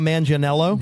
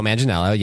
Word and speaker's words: Manganiello: I'm going to say, definitely a rom-com with her and Joe Magliano. Am I saying his name right? – Joe Manganiello. – Joe Manganiello, yeah.